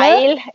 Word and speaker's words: Weil: Og så husker jeg at uh, du kanskje, uh, la på bordet Simon Weil, eller Weil, eller hvordan man uttaler det? – Weil, ja, det stemmer.